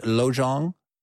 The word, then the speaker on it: Lojong: Is it, how do I pronounce that? Lojong? – Yes.